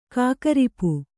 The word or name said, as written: ಕಾಕರಿಪು